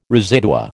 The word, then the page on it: residua: American English